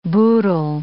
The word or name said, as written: boodle